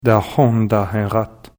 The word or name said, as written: Tahontaenrat